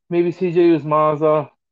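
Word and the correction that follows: Uzomah: And I probably butchered his name. – You mean Uzoma? – Uzoma. Thank you.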